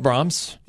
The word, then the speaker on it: Brahms: So anyway, we love Brahms. – Yeah. – And it rhymes with Father Tom's.